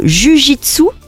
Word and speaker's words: jiu-jitsu: Vous avez même été prof de jiu-jitsu. – C'est ça, vous l'avez bien prononcé.